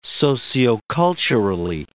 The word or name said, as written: socioculturally